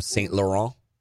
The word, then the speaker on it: Saint Laurent: I got some boots from Saint Laurent. Am I saying that right? – Nailed it.